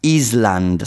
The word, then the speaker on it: island: Um, if I am on, uh, on a desert island Island, scritto isola in inglese, però non si pronuncia l'S. It's island. Island è isola.